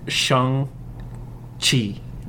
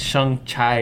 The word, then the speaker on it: Shang-Chi: But it is pronounced Shang-Chi, right? – Yes. Yes. – Okay, because I would always say like Shang Chai or something like that. – Yes. – But I was just completely wrong.